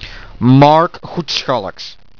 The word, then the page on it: Marc Goetschalckx: Flemish